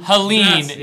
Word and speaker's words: Helene: I don't know how to spell it, but it's pronounced Helene. – Helene, yes.